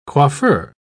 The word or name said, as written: coiffeur